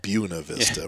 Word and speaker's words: Buena Vista: And It's still hard to call it Buena Vista. Yeah. Our Southern California roots tell us it should be Buena, but that's how they pronounce it.